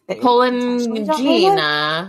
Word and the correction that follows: Polignano: Uh, Polignano. Polignano. That's where they are. Perfect pronunciation. Thank you.